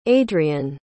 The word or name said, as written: adrien